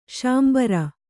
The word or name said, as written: ಶಾಂಬರ